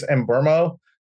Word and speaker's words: Mbeumo: I always say his name wrong. I say it in Mbeumo. I think it's Mbeumo with like an R sound.